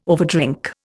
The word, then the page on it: overdrink: Indian